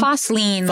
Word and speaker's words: Fosslien: It's Foslin, like Lean Against a Wall.